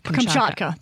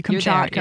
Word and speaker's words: Kamchatka: Kamchatka. – Thank you. Kamchatka.